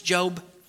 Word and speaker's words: Job: I know, it's tricky. It's Job. I realize it's spelt the same way.